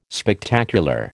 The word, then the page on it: Spectacular: American English